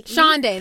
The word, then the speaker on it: Shonday: and she plays Sunday, or it's spelled S-H-O-N-D-A. – Shonday. That was Shonday.